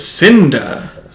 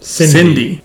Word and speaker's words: Cinda: Eric's like, I got a date with Cinda. – Yeah. – And Alan's like, why don't you just say her name? Cindy. Because her name is Cinda.